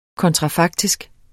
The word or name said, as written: kontrafaktisk